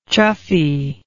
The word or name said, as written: chuffiness